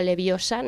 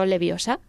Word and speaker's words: Leviosa: La señorita que nos enseñó que se pronunciaba Leviosa, no Leviosa, sí.